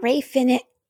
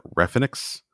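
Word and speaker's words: Rey Fénix: Then we got Rey Phoenix. Excuse me. Rey Phoenix versus Angelico. – I was worried that you were going to start pronouncing it Refinix.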